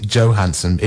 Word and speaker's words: Johansson: And I think you pronounce it Johansson, if she's to be believed. But that's not a criticism of you, because I think she's wrong.